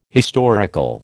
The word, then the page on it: historical: American English